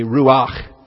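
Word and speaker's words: ruach (רוח): The second term there is the ruch, or the ruach, I guess. I like to keep my furtive pathax rather furtive, and so I barely pronounce the A sound there. I just call it ruch, but others may want to pronounce the ruach a little bit more pronounced.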